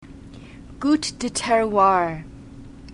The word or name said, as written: gout de terroir